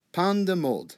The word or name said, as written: pan de molde